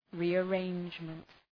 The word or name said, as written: rearrangement